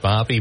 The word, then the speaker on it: Bobi: So anyway, again, I think his name is pronounced Bobby, B-O-B-I.